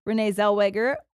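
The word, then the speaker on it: Renée Zellweger: Renee Zellweger, is that how you say it? – Yeah.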